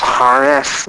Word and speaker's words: charis: And the Greek word for grace, it was a word spelled C-H-A-R-I-S. But it's pronounced Harris. That's how the guy said it on the internet today.